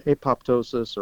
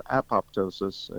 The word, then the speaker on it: apoptosis: It has nothing to do with popcorn, but apoptosis or apoptosis is the normal pronunciation in the U.S., is programmed cell death.